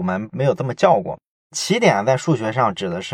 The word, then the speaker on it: 奇点: 但是在别的地方，比如说物理上啊，或者说其他地方，我们也经常看到有人把它念作基点。但是咱们这次要讲这本书之前呢，啊，我又好好的思考了一下，如果这个词来源于数学的话，我们最好就尊重数学上的这个发音，就按起点这么念就行了。